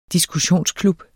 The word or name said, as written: diskussionsklub